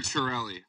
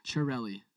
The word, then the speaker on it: Chiarelli: Peter Chiarelli. – Chiarelli. So it's like Chia Pets.